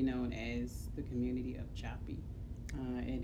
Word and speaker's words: Joppa: Well, on paper, it is spelled J-O-P-P-A, and so phonetically, one would call it Joppa, but the locals and people who have been in the community for generations pronounce it in various ways, and so one of the ways that, one of the pronunciations that has stuck is Joppy. I've heard Jaffy, I've heard... the spellings have been different, J-O-P-P-E-E, J-O-P-P-Y, J-O-P-P-A is the spelling that is on paper, on maps okay.